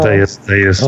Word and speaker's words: Deus: I don't know how to say this, Deuce Vault. – Oh, Deus.